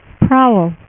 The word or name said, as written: Prowler